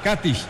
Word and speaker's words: Katić: O Katić, ellos le ponen el acento en la A. Katić, Nikola Katić.